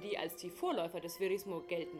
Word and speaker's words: Verismo: nämlich dem Ferismo. Verrismo? Geht es da um Verrisse von Opern in den Zeitungen damals? Nein, ich habe das natürlich ganz falsch ausgesprochen. Verismo.